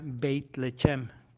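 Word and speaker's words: Beit Lechem (בית לחם): Si juntamos, como para decir casa de pan, se, se diría Beit Lechem, que está muy cercano a su traducción en inglés Bethlehem, que en español es Belén.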